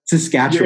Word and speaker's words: Saskatchewan: Saskatchewan.